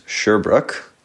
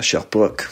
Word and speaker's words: Sherbrooke: So the way that you pronounce Sherbrooke, if you're an English speaker, is Sherbrooke, like you're sure that it's a brook. And if you're a Quebecois, you would pronounce it Sherbrooke. Sherbrooke.